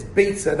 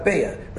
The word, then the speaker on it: Beitza: The answer is fairly obvious because he's trying to point out that the pronunciation of the Masechta is Beitza, not Beya. Right? So, Aschum Masechtas Beitza, be'ezras gadol ha'itza. Right, comparing the word Beitza to Eitza, the Chur is trying to show the pronunciation.